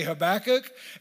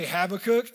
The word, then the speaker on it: Habakkuk: Sometimes I say Habakkuk and sometimes I say Habakkuk. And it really just comes down to what emphasis I want to put on what syllable.